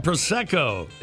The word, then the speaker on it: Prosecco: Prosecco.